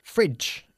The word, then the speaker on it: fridge: Fridge. Es más corto el sonido de la vocal.